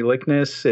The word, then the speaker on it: Likness: That makes it a little bit simple because it expands out to my name, Jeremy Likness. It's uh, likeness without an E or likeness without a C, however you prefer it, but people seem to misspell it.